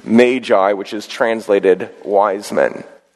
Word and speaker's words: Magi: So first we take up the Magi. I do believe that's the correct pronunciation, although some people say magi or other things.